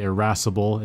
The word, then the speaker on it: irascible: This is a very fun word to say, irascible. It's spelled I-R-A-S-C-I-B-L-E. That's I-R-A-S-I-C-B-L-E, irascible.